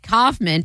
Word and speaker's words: Kaufman: And Kaufman, pronounced Kaufman, rhyming with cough plus man.